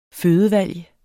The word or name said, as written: fødevalg